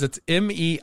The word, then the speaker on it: Meiomi: Meiomi Pinot Noir. Is that how, am I saying that right? – Yeah. – Okay. So guys, it's Meiomi.